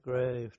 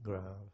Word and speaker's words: Grave: And some of my colleagues said, it looks like grave to me, you know. I said, well, it's spelt the same, but they pronounce it grave.